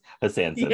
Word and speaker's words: Hassansins: I may have not even pronounced it right, because like there's an N in the middle and I don't think I pronounced that N. – Yeah. It's a made up word. – Hassansins. That's what it is. Hassansins.